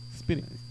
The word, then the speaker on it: Speedy: ¿O Spidey? ¿Cómo se pronuncia? ¿No? – Speedy.